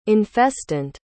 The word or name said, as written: infestant